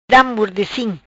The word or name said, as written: Da mordecim